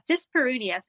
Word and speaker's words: dyspareunia: So what is I'm probably going to say it wrong. Dyspareunia? – Yeah, dyspareunia.